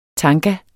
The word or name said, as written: TANGAER